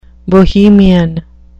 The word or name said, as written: bohemian